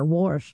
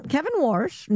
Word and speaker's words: Warsh: Well, see, and I just kind of said it wrong myself. I didn't say enough R there, Warsh. – Yeah, but throw that R in there. – Yeah. Kevin Warsh, new chairman of the Federal Reserve.